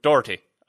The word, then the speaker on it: Doherty: We have been called up on the pronunciation of that Irishman's name. It's Doherty.